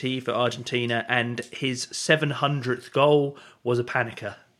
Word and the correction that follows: Panenka: A Panenka. – Panenka. – Yes, a Panenka penalty, like, amazing. I always say it wrong.